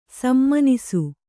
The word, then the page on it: ಸುಮನಸ್ಸು: sumanassu